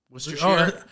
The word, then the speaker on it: Worcestershire: Worcestershire I'm very impressed you could pronounce that because Worcestershire.